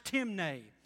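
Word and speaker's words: timē: It's spelled T I M E like in English time, but it's pronounced timne.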